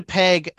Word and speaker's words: Peg: Peggy. – One syllable too long. It's Peg.